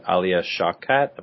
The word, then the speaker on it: Alia Shawkat: I should just point out before we sign off for the week that Alia Shawkat, am I saying her name right, from Arrested Development? – Sure. Yeah.